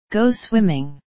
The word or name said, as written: go swimming